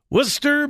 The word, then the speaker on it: Worcester: Worcester, Massachusetts. I have no idea why we pronounce W-O-R-C-E-S-T-E-R as Worcester, but we do.